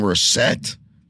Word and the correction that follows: Russett: Uh, Russett. – Russett.